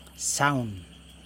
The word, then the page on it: saun: Yokoim